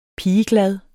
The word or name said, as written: pigeglad